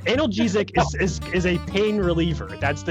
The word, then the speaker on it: analgesic: Alcohol was used for pleasure and for what's called an analgesic. Right, that's how you say it, analgesic.